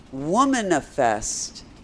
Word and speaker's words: womanifest: He says, "Womanifest your dreams." Actually, I think you pronounce that womanifest, like manifest, except womanifest.